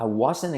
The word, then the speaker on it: wasn't: I wasn't expecting that. I wasn't. I wasn't. No me esperaba eso. I wasn't. La T de wasn't no se pronuncia.